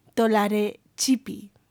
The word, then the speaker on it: Tolaretxipi: Y bueno, se llama Eli Tolarechi. No, el apellido lo he dicho mal. Tolarechipi.